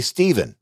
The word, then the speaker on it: Stephen: My name is pronounced Esteban in Spanish. In English, we say Stephen, or Steve for short.